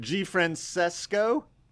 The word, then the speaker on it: G. Francesco: And G-Francesco, how's it going? – Yeah. Well, Did I pronounce that correctly? – The legal name change has taken place. G. Francesco.